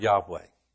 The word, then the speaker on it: Yahweh: And that, once again, when you see the word Lord in all capital letters in your translation, it's the word Yahweh. Okay? Or Jehovah, but that's the English pronunciation.